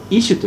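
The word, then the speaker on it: イ シュ (איש): これは男、男はイシュと言います。イシュから取られたのだから。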